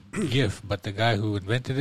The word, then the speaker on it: GIF: Just GIF, like the peanut butter, GIF.